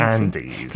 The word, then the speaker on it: Andes: 거리에서 탱고를 추고 축구의 열정도 느낄 수 있고 또 우리가 이제 안데스 산맥이다 이렇게 이야기를 하는데 아까 어떻게 발음을 하셨는지. – Andes. – 그죠? And, Andes. – In English.